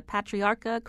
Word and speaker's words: Patriarca: Starting with, if I say this correctly, the Patriarca crime family.